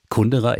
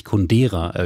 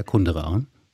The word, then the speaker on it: Kundera: Oder möglicherweise nicht Jörg Plath als Literaturkritiker, als jemand, der Milan Kundera, ich muss übrigens an dieser Stelle sagen, ich habe viele Jahre gedacht, der Name spricht sich Kundera, Jörg Plath, aber nein, die tschechische Sprechweise ist wirklich eher Kundera.